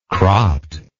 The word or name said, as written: cropt